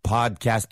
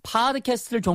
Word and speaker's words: podcast: What's the best podcast app on the market? – 네, APP는 app이 맞고요. 그다음에 팟캐스트를 종종 우리말로 줄여서 다들 팟캐스트, 팟캐스트 하는데 틀린 발음이에요. Podcast가 맞습니다.